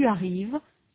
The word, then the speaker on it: arrives: Note in particular that the S of tu arrives is not pronounced, nor is the final NT of ils arrivent.